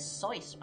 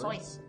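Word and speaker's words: Joyce: The proper pronunciation is Soyce, by the way. Did you know that? – Soyce? Is it really? – Soyce. – Dr. Seuss. – It's supposed to rhyme with rejoice.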